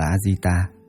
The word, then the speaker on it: Ajita: Ngài A Giật Đa, tiếng Ấn Độ đọc là Ajita, chữ Hán là Vô Năng Thắng, là tên riêng của Ngài Di Lặc Bồ Tát, tiếng Ấn Độ đọc là Maitreya.